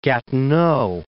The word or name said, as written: Gatineau